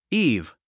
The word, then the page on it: eve: íːv; イーブ